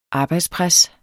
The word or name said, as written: arbejdspres